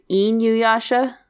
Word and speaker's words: Inuyasha: I mean, if you want to do it, if you want to do it like proper, I think it's Inuyasha. Because I's are E's, are pronounced E's in Japanese.